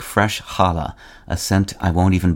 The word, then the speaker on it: challah: Today, challah, C-H-A-L-L-A-H, and the anglicized plural with an s are the variants usually encountered by English speakers.